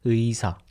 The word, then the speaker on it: 의사: You just need to quickly pronounce 의 and 이 together like 의, 의, 의사.